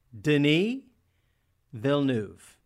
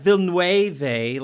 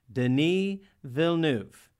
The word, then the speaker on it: Denis Villeneuve: So, every time you hear me say that, just know that his name is pronounced Denis Villeneuve. – Like Denis Villeneuve. – Yeah, like I can do that. – Like the last name of your favorite director, Claire Denis.